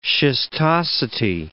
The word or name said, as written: schistosity